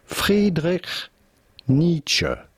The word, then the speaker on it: Friedrich Nietzsche: Now, his name in German yes. – would normally be pronounced Friedrich Nietzsche. Friedrich Nietzsche. However, in English, his name is normally pronounced Friedrich Nietzsche.